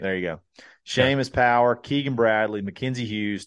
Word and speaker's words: Seamus: It looks like Seamus. I'm pretty sure it's pronounced – It is Seamus.